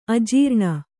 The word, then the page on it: ಅಜೀರ್ಣ: ajīrṇa